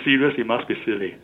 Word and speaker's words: SILI: SILI, pronounced as silly.